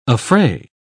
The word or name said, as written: affray